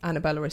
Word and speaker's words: Anabela: And we don't have double L in Portuguese either, which is why Anabela is spelt with one L.